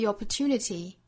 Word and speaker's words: opportunity: 第一句呢就有很多有差别的地方啊，像第一个词要注意的就是 opportunity 这个词跟英式发音其实是很大差别的，像第一个元音英音的是 o，到美音之中呢就发 o upper，第二个音节包含字母 r，所以发成 r upper 后面这个 tu 呢发 tu 最后 nitty 尾部的这个 d 是一个弹舌音弹出来的，所以这个词的音和美发音差别很大哈。